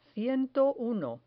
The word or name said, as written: ciento uno